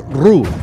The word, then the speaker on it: ro: se escribe ro, pero se dice ru.